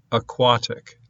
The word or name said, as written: aquatic